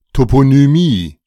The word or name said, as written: Toponymie